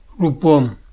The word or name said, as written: Pont